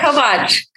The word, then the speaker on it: Kovach: Did I say it right? Kovach or Kovach? – Yeah, Kovach. Kovach.